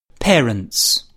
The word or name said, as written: parents